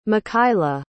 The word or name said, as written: mikhaila